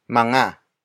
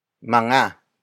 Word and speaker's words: mga: But first, we have mga. Mga. Mga. Mga is spelled as M-G-A.